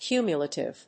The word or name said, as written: cumulative